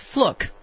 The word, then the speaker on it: Fluke: Well, you know, it was first Fluke and then Fluck, and then apparently it's Fluke is the actual pronunciation.